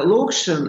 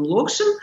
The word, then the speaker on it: Локшин: То есть, конечно, Локшин, я первый раз слышу, что так ударение делается, но поверю вам, что он Локшин.